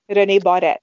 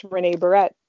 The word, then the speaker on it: René Barrette: So say it for us in French. – René Barrette. – Ooh, that is pretty. – Very exotic. – Yeah. Mm-hmm. And then in English, it's just René Barrette.